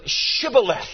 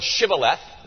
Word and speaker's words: Shibboleth: Shibboleth. Now, did you notice when I read the text, did you notice that there was a difference? The difference lies in an H.